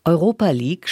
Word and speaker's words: Europa League: Europa League spricht man so aus. Europa League.